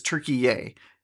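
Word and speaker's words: Türkiye: And then also, I don't know if you've noticed, but Turkey has asked that we start pronouncing their country name as Turkiye. That's how we're supposed to pronounce it. That's the Turkish pronunciation of Turkey, Turkiye.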